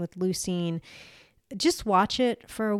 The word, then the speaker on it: leucine: There is something they're now making capsules with called L-leucine or leucine. It is spelled L-E-U-C-I-N-E.